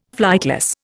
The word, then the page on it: flightless: Indian